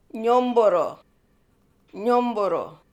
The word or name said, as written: Nyoomboorroo